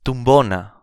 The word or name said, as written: tumbona